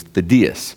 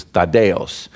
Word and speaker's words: Thaddeus: So just to clarify, the Greek pronunciation, or at least the English version of the Greek pronunciation that my parents gave me, was Thaddeus. Now, my wife and I traveled to Greece last summer, and they said it was Thaddeus.